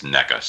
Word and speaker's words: NECA: And I wish somebody, Nika, or I think it's NECA, sorry, I pronounced it wrong,